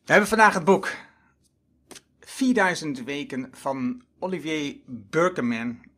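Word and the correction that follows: Oliver Burkeman: of hoe je het ook mag uitspreken, Oliver Berkman. Het is een Engelsman.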